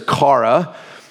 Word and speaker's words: chara: Greek word there is chara. C-H-A-R-A is how you spell it in English.